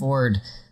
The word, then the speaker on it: Fjord: And you only say the J when it's a joke because it's spelled Fjord, but it's pronounced Ford.